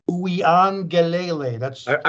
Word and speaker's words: Uiagalelei: Thanks to quarterback DJ Uiagalelei. I think I said that right. Uiagalelei.